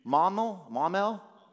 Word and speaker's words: Maumelle: There's this dude named Kevin Kennard, and he's from a place called Maumelle. How do you say it? One person. Maumelle.